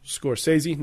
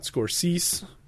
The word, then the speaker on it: Scorsese: But boy, oh boy, if it didn't lead to one of the greatest movies ever made, 1990's Goodfellas, directed by Martin Scorsese, pronounced Scorsese, not Scorsese, as some have joked.